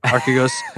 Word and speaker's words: Archegos: And it's been the big event of the talk on the street, and it's this Archegos. Nobody can say it right. Archegos.